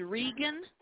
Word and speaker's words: Regan: Did you say your last name is Regan? – Yes. – How do you spell that, Gloria? – R-E-G-A-N.